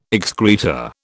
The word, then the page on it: excreta: British English